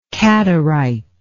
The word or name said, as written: qadarite